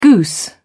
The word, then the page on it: goose: British English